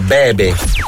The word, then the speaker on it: Bebe: Bibi Rexha, David Guetta, Sad Girls. – No, e non è Bibi Rexha, Giovanni, è Bebe.